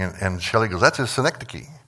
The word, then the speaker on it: synecdoche: It's synecdoche. And so I've got the pronunciation guide up there, synecdoche.